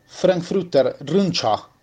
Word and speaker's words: Frankfurter Rundschau: Hoy el diario Frankfurter Rundschau, así se pronuncia según mi alemán, y lo cita Bayer porque es un diario que no suele atacar a la alta alcurnia europea, se atreve a decir basta.